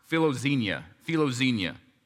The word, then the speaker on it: philoxenia: If you were to take that Greek word hospitality, it's pronounced philoxenia, okay?